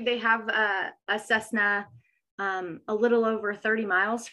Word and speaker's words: Cessna: So I pronounced that right. I was worried I was going to mispronounce it. Correct. Cessna.